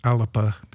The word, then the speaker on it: Alba: It's A-L-B-A, so everybody goes Alba, but it's Alapa because Gaelic is one of those languages that's very rhythmic, and if they feel like it needs an extra syllable, they'll just be very, very confusing.